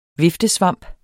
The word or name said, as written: viftesvamp